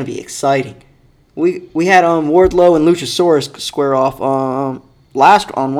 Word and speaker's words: Wardlow: How do you say his name? Wardlow? – Wardlow. Yeah, I'm pretty sure it's just Wardlow.